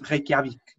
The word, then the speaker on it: Reykjavik: Reykjavik tem a, a, a capital com o nome mais difícil de sempre, que se escreve Reykjavik e diz Reykjavik.